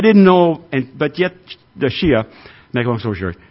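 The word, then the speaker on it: Schia: It's S-C-H-I-A, pronounced Shia.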